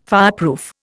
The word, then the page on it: fireproof: Indian